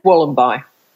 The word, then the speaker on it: Wollombi: Wollombi.